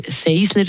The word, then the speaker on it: Sensler: Wir sind da, weil ihr beide Mitorganisatoren seid, müsst korrigieren, falls ich es falsch sage, von der Seisler Secondhand Fashion Show, die am 7. Oktober zu Tafers im Gymnastikraum bei OS Tafers oberhalb von der Donau steht stattfindet. Habe ich es richtig gesagt? – Richtig, ja.